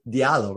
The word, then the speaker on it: Diallo: So check it out, his name is spelled Mamadou, like m-a-m-a, like mama, Dude, D-O-U, and his last name is D-I-A-L-L-O. How do I pronounce your last name? It's Diallo, right? – Diallo, yeah.